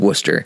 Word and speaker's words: Worcester: That's not Worcester, it's Worcester. Now that I'm in here in New England, I have to learn how to pronounce things right.